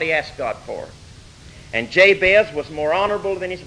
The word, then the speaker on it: Jabez: His name is Jabez. It wouldn't be pronounced that way in Hebrew. But anyway, we'll give it the English pronunciation, Jabez.